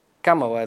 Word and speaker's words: Камала: Камала или Камала? – Камала. – Камала е, да. – Камала е, Тръмп го произнася като Камала и демократите му се скараха, че не е правилно.